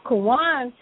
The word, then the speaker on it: Kawan: Now, Kawan, you know, it sounds like the word Quran, which had to do with step by step.